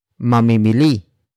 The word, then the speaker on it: mamimili: Word for word it's mamimili ng sapatos si Christian mamayang gabi. Mamimili ng sapatos si Christian mamayang gabi. Mamimili ng sapatos si Christian mamayang gabi. The first word is mamimili.